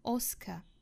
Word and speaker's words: Oscar: 比如说我们看第一个单词 Oscar， Oscar，它的尾部是小刷音不要读成了是 Oscar， Oscar。